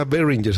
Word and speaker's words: Behringer: La marca se llama Behringer, se escribe B E H R I N G E R, así como B H Ringer, ¿no?